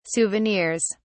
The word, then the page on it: souvenirs: British English